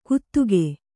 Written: ಗುತುಗೆ

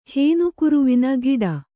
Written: ಹೇನುಕುರುವಿನ ಗಿಡ

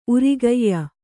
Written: ಊರುಗಾಯಿ

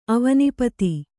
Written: ಅವನೀಪತಿ

